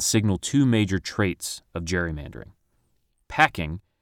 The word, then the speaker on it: gerrymandering: And although the cartoonist was probably using a hard G to evoke Gary's surname, the term stuck. We now use gerrymandering to refer to maps we feel are, for one reason or another, unfair.